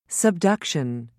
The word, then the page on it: subduction: British English